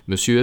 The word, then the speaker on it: monsieur: Monsieur es una palabra un poco especial. Olvídense de buscar una explicación de por qué M-O-N se pronuncia me. Olvídense. Apréndanse la palabra así, monsieur, y sin hacerse demasiadas preguntas. Aparte del sonido U cerrado, tenemos el otro abierto.